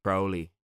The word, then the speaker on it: Crowley: So my name is pronounced Crowley. But I prefer pronouncing it Crowley, just because it's almost like taking that magical power.